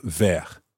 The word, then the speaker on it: vert: So if we wanted to say a green book, then we would say un livre vert. Now notice that you say in French, un book green, un livre vert. In most situations, the adjective in French comes after the noun, un livre vert.